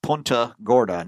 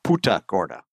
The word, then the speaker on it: Punta Gorda: It's Punta Gorda, not Puta Gorda.